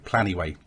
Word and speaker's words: Planyway: But what I use now is Trello with an add-on called Pl Planyway, Plenyway, Planyway. So I'll, I'll put the links on because all of these have weird spellings, but it's spelt P-L-A-N-Y-W-A-Y.